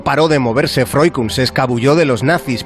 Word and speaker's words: Freuchen: Eh, mira, fíjate, me estás hablando de Freukun, ¿no? – Se pronuncia así.